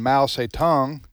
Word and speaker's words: Mao Zedong: namely Joseph Stalin and Mao Zedong, did I pronounce that correctly? – Yes, and Adolf Hitler.